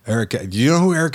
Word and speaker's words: Eric Kath: I haven't pronounced this right. Eric Kath. – Yeah, Eric Kath.